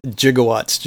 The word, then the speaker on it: gigawatts: and that's how he pronounced gigawatts. And so they basically kept that in the movie. So apparently either pronunciation is correct, but yeah, Jigowatts just sounds so much goofier, I think, and it just stuck, you know? – What the hell's a Jigowatt?